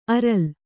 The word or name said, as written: ಆರೆಲು